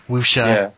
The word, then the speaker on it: wuxia: And those are one kind of movies where I think that they're kind of closely tied, especially like the, I believe it's pronounced wuxia or wuxia, yeah.